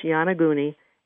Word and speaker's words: Yonaguni: It's pronounced Yanaguni, and it's Yonaguni. And if you type that in, you should, and then under sea ruins, you should be able to find them.